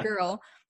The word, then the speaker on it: Ghrl: And for anyone listening, it is spelt golden, like you usually spell golden, but then it's also spelt the girl part, G-H-R-L, like girl, you can pronounce it girl.